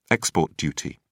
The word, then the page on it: export duty: British English